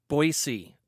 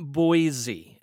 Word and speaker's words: Boise: So Royal Coffee Roasting in Boise. And you have to say it right. It's not Boise. It's Boise.